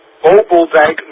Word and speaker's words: Bobbeldijk: Bobbeldijk, Noord-Holland, zo is het eigenlijk uitgesproken behoort te worden, maar iedereen spreekt hier uit zijn Bobbeldijk.